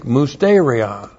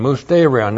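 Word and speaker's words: musterion (μυστήριον): And specifically, a certain portion of the God-breathed scriptures, and that is called in the Greek musterion. M-U-S-T, long E-R-I-O-N, musterion. Now it's transliterated mystery.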